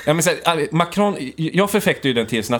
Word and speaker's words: Macron: Det var ju den här utfrågningen av Macron. – Ja. – Och jag säger ju här ett svenskt uttal på Macron. Jag säger inte Macron.